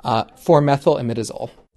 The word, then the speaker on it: 4-methylimidazole: As for 4-MEI in the caramel coloring, its full name is pronounced 4-methylimidazole.